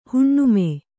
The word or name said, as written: ಹುಣ್ಣಮಿ